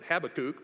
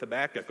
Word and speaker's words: Habakkuk: Some people pronounce it Habakkuk, but I'm going to go with Habakkuk all day because it just sounds cooler.